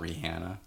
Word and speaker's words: Rihanna: We had a librarian that used to say Rihanna, and I have to be like, Mrs. Abel, it's not Rihanna. The H is silent.